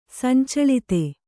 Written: ಸಂಚಳಿತೆ

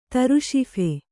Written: ತರುಶಿಫೆ

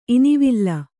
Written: ಇನಿವಾಲ್

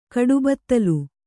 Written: ಕಡುಬತ್ತಲು

